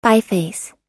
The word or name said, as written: biface